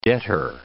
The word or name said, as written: debtor